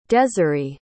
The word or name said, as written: deziree